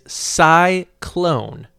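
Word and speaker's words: Sy-Klone: His name is Cyclone. Let me spell that for you. S-Y hyphen K-L-O-N-E.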